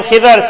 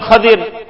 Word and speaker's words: Khidr: Some call him Khidr, some call him Khadir. You can pronounce it both ways.